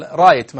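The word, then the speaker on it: right: وهذه طبعا مثل رايت مثلا، كلمة رايت فيها حرف جي اتش ما تنطق، ولها أمثلة.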